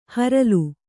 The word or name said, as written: ಹರಲು